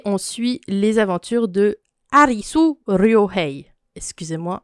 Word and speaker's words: Arisu: Euh, petite euh, fun fact, Arisu, c'est la prononciation japonaise de Alice.